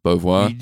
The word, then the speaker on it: Beauvoir: I can't say her name properly. – Beauvoir. You nailed it, yeah.